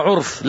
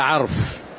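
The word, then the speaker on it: عَرْف: بعض الطلاب يقرأوها عرف، لا، ليس عرف، العرف المعروف، إنما هي تنطق هكذا عرف، يعني من طلب العلم ليصيب به عرض من عرض أعراض الدنيا لم يجد عرف الجنة، أي ماذا؟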